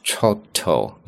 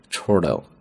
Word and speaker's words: chortle: chortle 注意啊，本来英式发音读作 chortle，那么美式发音 chortle chortle，它指的是幸灾乐祸的笑。